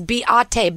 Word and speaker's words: Behati: No, her name is Beate, by the way. People have been mispronouncing her name for years. It's not Beate. – Not Beate? – It's Beate. – Beate. Beate? – Beate, no H.